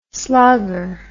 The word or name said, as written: slogger